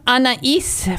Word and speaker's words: Anaïs: It's pronounced Anaïs for all of you people who see that name. And I, as Kim said, when I was young, and I also started reading her when I was a teenager, and I didn't know how to pronounce it. It's because it's spelled A-N-A-I-S, and I got it all wrong too.